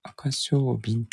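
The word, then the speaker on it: 赤翡翠: もっちゃん、もっちゃんはどうなっちゃったんでしょうか。いるのかな寝ちゃったかなえー、赤カワセミって書くってことですかそれで赤小瓶って読む、赤小瓶って読むんです士さんも見たんですね。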